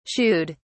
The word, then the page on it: shooed: British English